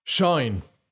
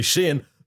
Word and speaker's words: Shein: Shine. Sheen? Shane? Well, however you pronounce it, and by the way, it's actually Shein, the world's largest fashion retailer, Shein, has taken the world of fast fashion by storm.